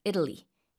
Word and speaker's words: Italy: 이탈리아라고 우리는 한국어로 말하지만 영어로는 강세가 일단 앞에 있고요. 삼음절이고 북미권에서는 T 발음도 잘안 들립니다. 그래서 Italy 이렇게요.